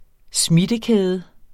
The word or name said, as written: smittekæde